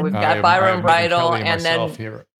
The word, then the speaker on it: Bridle: And Kelly, you and I will be in here next week with Dr. Brindle, is that how you pronounce his last name? – Byron Bridle, yes. – Wish we could get Kelly back on Twitter. – Bridle.